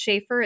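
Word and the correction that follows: Shaffer: It's Schaffer.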